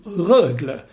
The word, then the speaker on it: Rögle: Alltså, Rögle. Nej, så du ska på tung betoning på första som Rögle.